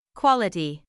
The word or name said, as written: quality